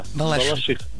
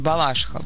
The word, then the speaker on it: Балашиха: Балашиха. – Мне даже рассказывали, почему мы норовим сказать Балашиха. Потому что у одного певца популярного в России была песня такая, где он поет Балашиха. Поет неправильно.